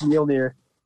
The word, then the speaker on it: Mjolnir: He could Jordan could lift my Lanier for sure. – Moliner. – Yeah. – Is that how you say my Lanier? Is that how you say it? My Lanier. – No, it's Mjolnir.